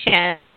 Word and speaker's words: Chen: I mean, that's Shen. – Okay, C-H-E-N. Yeah. – Shen. Okay, great. So, you know, because I know that it's, you know. But who would you say Tony Shen is, like, you know, how would you describe yourself?